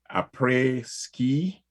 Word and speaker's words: Après Ski: She landed the role of CEO in Slice's reality TV show, Après Ski. – How do you say that? – Did I say that correct? – That is correct. Yes.